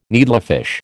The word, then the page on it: needlefish: American English